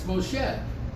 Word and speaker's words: Moshe: Well, how many people say Moshe? It's Moshe. My father always corrected me, but I...